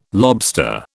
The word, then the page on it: lobster: British English